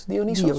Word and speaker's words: Dionysos: Dionysos, zeg ik dat goed? – Dionysos. Dionysos, volgens ja.